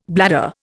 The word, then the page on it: bladder: Indian